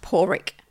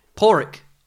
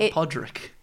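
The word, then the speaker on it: Pádraic: It's actually pronounced Porrick. – Porrick. Oh, it is Porrick. – Yes. – Yes, not Podrick.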